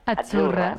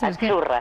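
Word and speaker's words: Azzurra: sí, como porque pizza, la Z no es la Z como decimos en catalán tan sonora, no. Es Achurra. – Muy bonito, muy bonito. – Es como pizza.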